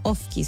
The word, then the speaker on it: Ofki: Los pueden seguir ahí tal cual, como Ofki. Se escribe O-F-K-I.